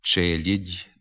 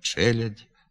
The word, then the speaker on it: челядь: У ненаголошеній позиції літературний звук А – у покутських, гуцульських, надністрянських говорах, як правило, заступається звуком «і» – «челідь», «десять», «тігнути». Така вимова в літературній мові не допускається. Треба вимовляти «челядь», «десять», «тягнути».